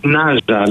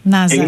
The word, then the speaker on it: Ναζα: Πώς προφέρεται το ντοκιμαντέρ? – Ναζα λέγεται. – Ναζα.